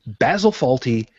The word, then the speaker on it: Basil Fawlty: And the reason why I wanted to hit that specifically was because the character of Basil Fawlty, and yes, I pronounced it correctly. Thank you. Basil Fawlty was so acerbic.